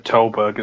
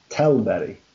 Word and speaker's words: Tällberg: All right, well, I know for a fact his pronunciation of Tällberg is wrong. Go on then, how should it actually sound? – Tällberg. – Ha! I knew it wasn't right.